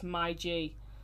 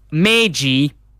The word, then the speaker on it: Meiji: And uh, they always set up, and the main thing they tend to say is "my G." and um, so they're probably loving that right now. – Yeah, well, Meiji, as it's actually pronounced, which will make the roadman sad now, Meiji University is one of Japan's leading private universities.